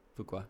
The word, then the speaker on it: Fuqua: Fakua's... – Did I say his name? Fakua? – Yeah, Fakua. – Fakua? – Fakua. – Fakua? I think it's Antoine Fakua. – Fuqua. – Yeah, it is Fuqua.